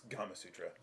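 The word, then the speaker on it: Gamasutra: Is it Gama Sutra or Gama Sutra? – It's Gama Sutra.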